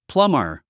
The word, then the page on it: plumber: plʌ́mər; プラマァ